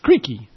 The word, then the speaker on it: Creque: The title of the song, which does not occur anywhere in the lyrics, is derived from Creaky, C-R-E-Q-U-E, or C-R-E-Q-U-I, pronounced Creaky Alley.